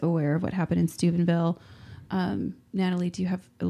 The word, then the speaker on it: Steubenville: I accidentally mispronounce it Stupidville sometimes when it's really Steubenville.